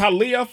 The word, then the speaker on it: Hialeah: It's Hialeah, Florida.